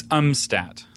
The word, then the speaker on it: Umstattd: It's umstat. – Umstat. Like, um, like you don't know how to pronounce it, and then stat as in really fast in a hospital.